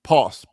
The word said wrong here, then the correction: pulse: Pulse. – Oh, Pulse. – That's what I said. – How did you say it again? – Pause. – Hold on, let's stop for a second here. – Pulse. – P-U. All right, say P-U-L-S-E. Pulse. – Paul. Say Paul. Say Paul. And Paul. No, not pa. Say Paul and pronounce the L.